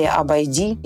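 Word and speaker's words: Обейди: Например, такой интересный сорт, как Абайде или Абайди или Абайди, если мы будем говорить по-арабски. Ударение будет на последнее слово, песок используется для производства белого.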